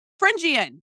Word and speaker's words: Frendjian: We have, hopefully I say her name correctly, we have an awesome guest, Lara Frendjian.